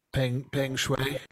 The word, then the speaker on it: Peng Shuai: And can either of the two of you give me the proper pronunciation so I don't Peng Shuai.